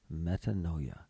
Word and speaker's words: metanoia: Metanoia. Metanoia. And it says pronunciation, metanoia. Metanoia. Greek. The Greek word, metanoia.